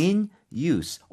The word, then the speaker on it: use: In use. 근데 쌤 지금 발음이 네. use가 아니네요. – 맞아요. In use. – 이렇게 use 이게 명사로 쓰이게 되면요. 그러면 use가 아니라 c처럼 발음하셔야 돼요.